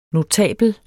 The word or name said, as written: notabel